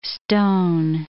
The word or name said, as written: stone